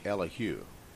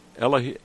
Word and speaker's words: Elihu: Um, before I learned, I learned in recently in Israel, talking to a couple of people, that in Hebrew they usually emphasize the last syllable on some of these words. – Oh, really? Elihu.